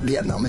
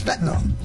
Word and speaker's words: Vietnam: She said, it's not Vietnam, it's Vietnam. And I would say, Mom, but I was there. She said, I don't care where you are. Yeah. It's not the way you pronounce it.